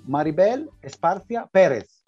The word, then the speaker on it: Maribel Esparcia Pérez: So I want to welcome today Maribel Esparcia Pérez.